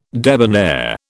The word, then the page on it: debonair: British English